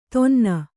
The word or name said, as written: ಥಣ್ಣನೆ